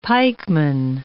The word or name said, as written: pikeman